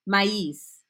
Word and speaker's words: maíz: Like, sin, so it's S-I-N without, so sin, S-I-N, maíz is how it's pronounced.